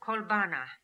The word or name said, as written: Kolbana